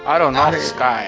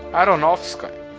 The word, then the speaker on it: Aronofsky: De Darren Aronofsky. Como é que fala o nome do cara? Aronofsky. Não, é o, o assessor para línguas estrangeiras é Xaropes. Aronofsky. Aronofsky.